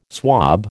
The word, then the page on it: swab: American English